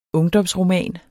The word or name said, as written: ungdomsroman